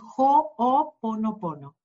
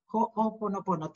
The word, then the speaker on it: Ho'oponopono: Es Ho'oponopono, Ho'oponopono. Tiene un apóstrofe, pero en el hawaiano pronunciamos cada letra como en el español y la H es, ¿no? Ho'oponopono.